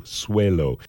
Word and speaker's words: Swaylo: His name is spelled S-W-A-Y-L-O, Swelo.